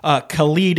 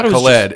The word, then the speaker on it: Khalid: Khalid is how you say his name. – Did I say Khalid? – That's what you said, right? – I think I said Khalid. – You did, but yeah. That's how you say it? – Yes, I'm pretty sure it's how it's. Okay. Yeah, it's not Khaled, it's Khalid.